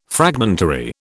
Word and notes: fragmentary: British English

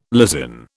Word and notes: lysin: British English

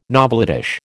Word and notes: novelettish: American English

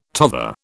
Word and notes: tother: British English

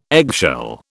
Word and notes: eggshell: British English

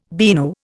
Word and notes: beano: Indian

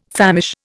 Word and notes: famish: Indian

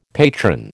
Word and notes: patron: American English

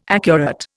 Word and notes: accurate: Indian

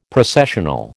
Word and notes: Processional: American English

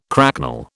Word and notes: cracknel: British English

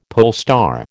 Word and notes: polestar: American English

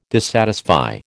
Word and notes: dissatisfy: American English

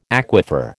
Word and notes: aquifer: American English

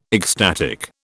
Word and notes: ecstatic: British English

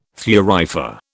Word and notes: thurifer: British English

